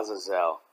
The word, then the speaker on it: Azazel: Azazel? – Azazel, yeah.